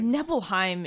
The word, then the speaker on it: Nibelheim: Nibelheim is definitely the way to pronounce it. – Yeah, because it's based on Norse.